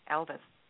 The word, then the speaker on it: Ylvis: It's by Elvis, and it's spelled Y-L-V-I-S, Elvis.